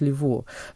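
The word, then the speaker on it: Leveaux: Наверное, Дэвид Лево. Дэвид Лево — это британский режиссер театральный, у которого, на самом деле, Лево там пишется с этими вот французскими окончаниями. Я поэтому так произношу, у него французская фамилия.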